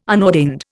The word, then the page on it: unordained: Indian